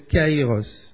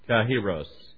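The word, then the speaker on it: kairos (καιρός): And it's a Greek word that is called kairos. – Et c'est un mot grec qui est prononcé kairos. – Everybody say kairos.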